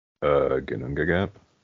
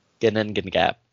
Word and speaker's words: Ginnungagap: Ginnungagap. – Ginnungagap.